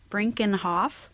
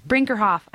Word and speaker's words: Brinkerhoff: and also Bill Brinkenhoff. Did I say it right? – Yeah, Brinkerhoff. – Brinkerhoff.